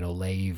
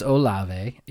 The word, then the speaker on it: Olave: first saw his name, I was pronouncing it Olave or something like that. But it's Olave.